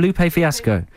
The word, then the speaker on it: Lupe Fiasco: Lupe Fiasco? – Yeah.